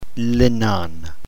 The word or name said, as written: linon